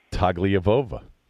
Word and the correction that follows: Tagovailoa: I know. And not even close. – You know what? I have to look at it phonetically to say it correctly. – Tagovailoa. – Tagovailoa, right. Yeah, Tagovailoa.